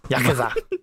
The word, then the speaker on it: Yakuza: Yakuza. – That's how it's pronounced. Yeah. You said that. – You said that so defeated. You said that so defeated. – I tried to be correct here. I tried to pronounce it as it should.